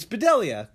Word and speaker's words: Bedelia: I'm going to mess up her last name. Damn it. Bonnie Bedelia. Bedelia. It's Bedelia. No, that's right. I think I'm saying that right.